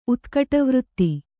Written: ಉತ್ಕಟವೃತ್ತಿ

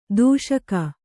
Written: ದೂಷಿಕೆ

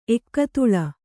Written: ಏಕತಾಲ

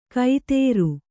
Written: ಕೈತೇರು

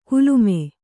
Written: ಕುಲುಮೆ

